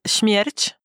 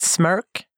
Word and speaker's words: Smärch: Uh, det uttalas Schmierch. Schmierch. Schmierch. Schmierch. Ja, ah, typ. Alltså det räcker. Folk har sagt smörk. Och det, det känns alltid lika roligt.